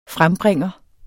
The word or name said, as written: frembringer